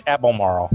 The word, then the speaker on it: Albemarle: or how do you say that? Albemarle. Along other charitable organizations which reflect LeRoy's spirit and passions. And I think it's Albemarle, right? – Okay, that would probably be right.